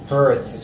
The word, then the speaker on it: Hearth: Hearth, so wie...